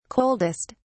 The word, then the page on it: coldest: British English